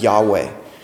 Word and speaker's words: Yahweh: And we don't know exactly how to pronounce it, but most often you'll hear it pronounced as Yahweh.